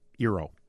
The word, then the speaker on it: Eero: And so this Eeros, is that how you say it? – Eero. Just singular.